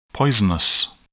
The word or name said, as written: poisonous